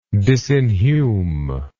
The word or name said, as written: disinhume